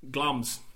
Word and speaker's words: Glamis: The latest one that's just come out is the 2015 version of Macbeth, which stars Michael Fassbender as Thane of Glamis Macbeth. – Glams, that's the one, thank you. Glams.